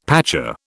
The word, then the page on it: pacha: British English